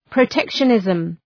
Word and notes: protectionism: prə’tekʃə,nızm

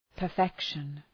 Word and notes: perfection: pər’fekʃən

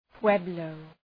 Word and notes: pueblo: {'pwebləʋ}

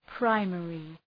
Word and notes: primary: ‘praımerı

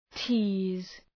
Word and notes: tease: ti:z

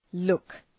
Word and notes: look: {lʋk}

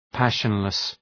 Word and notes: passionless: ‘pæʃənlıs

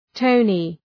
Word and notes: tony: ‘təʋnı

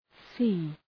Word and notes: sea: si:

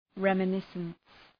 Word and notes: reminiscence: {,remə’nısəns}

